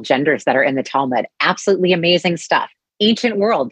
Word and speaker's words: Talmud: The Talmud.